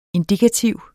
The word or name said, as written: indikativ